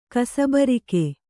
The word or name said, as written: ಕಸಬರಿಗೆ